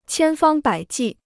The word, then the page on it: 千方百计: qiān fāng bǎi jì